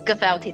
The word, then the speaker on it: Gifel Tea: Is that how you pronounce the name? – Yes, Gifel Tea, that's right.